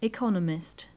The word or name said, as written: economist